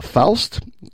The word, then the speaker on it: Faust: He was performing in a production of the opera Faust, I think it's pronounced. – Yes, it is.